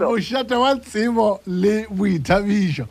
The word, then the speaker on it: Moshate Watsebo le Buitabiso: Do you say Moshate Watsebo ki Buitabiso? – Moshate Watsebo le Buitabiso.